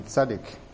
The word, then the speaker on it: tzaddik: The word in Hebrew used is tzaddik, and usually that word is used in the context of justice. If you remember that word, it rhymes with Melchizedek, and that actually means just King of Righteousness.